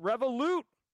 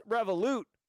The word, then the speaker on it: Revolut: It's pronounced Revolut. No, James McQuiggan, I know it's Revolut.